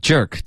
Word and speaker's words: jerk: Want, 嘴巴要张开，别发 when, and, and, went 是 W-E-N-T 的发音,OK? – What a jerk. – OK, jerk, 结尾的科没发，如果你想要省略科的音，你要导致一个干脆的停顿。